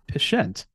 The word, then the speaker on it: Pshent: So that is known as the Pshent. So speaking of lack of silent P, there we have another hard P that is most certainly not silent.